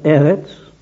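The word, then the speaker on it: Eretz (ארץ): Ich habe das Wort Erde, hebräisch gelesen, ausgesprochen Eretz und Himmel Shamayim. Eigenartig, unsere Erde ist ja nur, oder nur, ja, wie wir wollen, nur und doch, erstens, einer der zwei Brennpunkte im ganzen Weltall.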